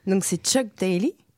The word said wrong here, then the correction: Daly: Est-ce que je l'ai bien prononcé, Sarah ? Ça y est, tu me valides ? – Enfin, on dit Dali selon moi, mais bon, Dali.